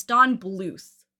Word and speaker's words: Bluth: It's Don Bluth.